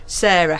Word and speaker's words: Sara: Now, Sarah, how is it spelt? Would you like to talk about your name and how it's spelt? – Yes, it's no H on my name. I'm Sarah, S-A-R-A.